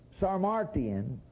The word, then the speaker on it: Sarmatian: Sarmatian, it's pronounced like carnation.